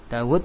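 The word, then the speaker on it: Dawud (داود): Dawud wawunya satu. Dal, alif, wawu, dal ya. Nah, tapi itu ada huruf wawu yang ada namun tidak tertulis. Dibacanya Dawud.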